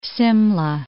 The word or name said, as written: Simla